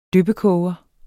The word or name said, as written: dyppekoger